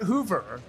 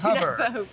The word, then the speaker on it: Hover: It's not Hoover. Hoover's a vacuum cleaner. It's Hover.